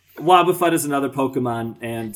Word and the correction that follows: Wobbuffet: That's not how you pronounce it. – How is it you pronounce it? It's Wobbuffet. – Well, I'm very bad at pronouncing things. – Wobbuffet. I hate it. – Oh, wait, so it's like Warren Buffett.